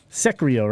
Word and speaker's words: Sekiro: Sekiro, right? How did I say that again? Sekiro. Sekiro. Whatever.